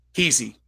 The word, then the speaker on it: Qissi: Kesey, Q-I-S-S-I.